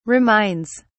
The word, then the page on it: reminds: British English